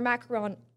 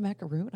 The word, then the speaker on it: macaron: And I've been saying it wrong. It's not a macaroon.